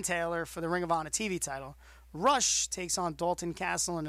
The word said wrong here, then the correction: Rush: Isn't it pronounced Roosh? – Roosh, you're right. Roosh. I apologize. You are right.